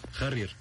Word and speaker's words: Harrier: Harrier.